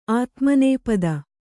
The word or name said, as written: ಆದಿಮುನಿಪತಿ